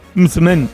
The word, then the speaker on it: msemen: Es una palabra complicada, decías M-S-E-M-E-N. Msmen, msmen, ¿vale? Y se pronuncia como lo ha hecho Hakima.